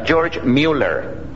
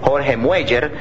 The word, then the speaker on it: George Mueller: George Mueller es la pronunciación de este nombre. Y si este hermanito estuviera vivo y escuchara a los españoles llamarle Jorge Mueller, yo creo que se va a poner muy disgustado.